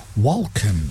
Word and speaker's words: Walken: Which is his impression of Dave Grohl doing an impression of Christopher Walken. – Thank you very much. – You actually indeed. – Do pronounce the L. – Do you? Oh, Walken.